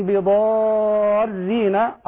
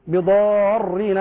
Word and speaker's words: بضارين: وما هم بضارين، الراء هنا مرققة، بضارين بعضهم يفخمها بضارين هكذا وهذا خطأ، الراء مرققة.